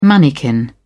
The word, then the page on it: manikin: British English